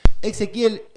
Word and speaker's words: Exequiel: Ezequiel, ¿está bien pronunciado? – Sí, sí, sí.